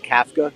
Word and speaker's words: Kafka: It's actually pronounced Kafka, and he's calling plays.